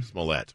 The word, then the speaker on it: Smollett: Smollett.